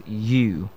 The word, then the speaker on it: ewe: Yes, and this word, E-W-E. Interestingly, this word people pronounce as Ewe. And I remember one of the WAC exams saw the word, and a lot of students could not pronounce the word. Yeah, and the word is simply pronounced as U. Just the same way you pronounce your Y-O-U. Yes, you. That's the way it's pronounced. And the second word is the word Y-A-C-H-T.